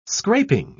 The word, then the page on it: scraping: British English